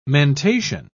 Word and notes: mentation: British English

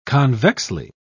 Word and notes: convexly: British English

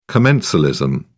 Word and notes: commensalism: British English

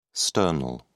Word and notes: sternal: British English

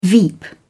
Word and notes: veep: British English

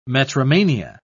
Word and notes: metromania: British English

